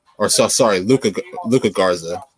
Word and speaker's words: Luka Garza: But then also, I mean, even though there are a few guys out there like Luke Zarga, who's been playing like exceptional basketball, and he's probably gonna continue to tear the Luka Garza, my bad. I said his name wrong.